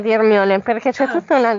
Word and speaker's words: Hermione: Senza neanche la H, Ermione. – Io non sto... – Con la E.